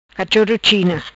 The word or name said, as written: Cachoro China